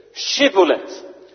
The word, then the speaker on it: shibboleth: Nu staat er in de Statenvertaling shibboleth, maar dat is de Hebreeuwse letter shin. Dus je moet het uitspreken als sh, shibboleth.